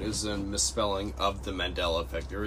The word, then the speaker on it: Mandela: Mandela, as in Nelson.